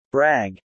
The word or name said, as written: brag